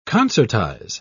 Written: concertize

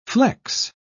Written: flex

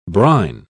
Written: brine

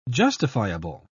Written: justifiable

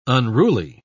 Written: unruly

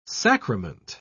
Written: sacrament

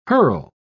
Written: herl

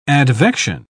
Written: advection

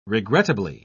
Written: regrettably

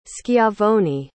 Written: schiavoni

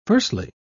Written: firstly

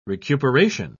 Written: recuperation

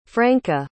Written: franka

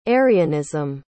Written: arianism

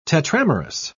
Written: tetramerous

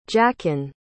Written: jachin

